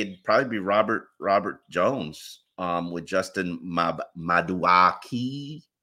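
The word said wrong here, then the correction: Madubuike: I don't know how to say his name. Maduike? – Maduike. – Say it again. Tell me. Maduike. Okay. All right. Look at you.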